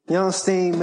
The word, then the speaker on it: Bernstine: That's spelled like Bernstein. Bernstine. All right. – Dope. So, Bernstine, man, got a crazy story.